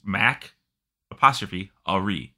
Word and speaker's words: Macquarie: And it's like, dude, it's literally like, it's Mac, apostrophe, Ari.